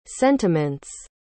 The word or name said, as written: sentiment's